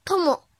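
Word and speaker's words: とも: 训读的时候读作 tomo tomo tomo，比如朋友 tomodachi tomodachi tomodachi，写成日语汉字是朋友的友加发达的达，友达达是繁体字。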